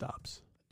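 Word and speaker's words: Doubs: Dubs onto there. – I think it's pronounced Dobbs.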